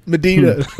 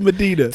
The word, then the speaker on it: Medina: I spell it median and it so confused me because like it's not median spirit. – It's Medina.